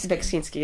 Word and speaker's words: Beksiński: But, you know, when I was in Poland recently, I went to I can't pronounce his name, Beskiński. – Beksiński, yeah.